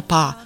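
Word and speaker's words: pá (八): Vyslovuje se jako pá.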